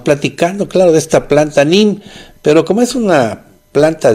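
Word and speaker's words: neem: se escribe NEM con doble, así se escribe. Nada más que se pronuncia NIM, como si el par de letras E fuera una sola I latina.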